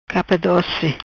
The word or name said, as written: Capa Doci